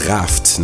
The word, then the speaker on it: Raft: Anschließend, mal wieder inspiriert von der Frankreich-Sendung von Ukasa aktuell, Raft oder Raft, ne, französisch ausgesprochen, Yaka Danse.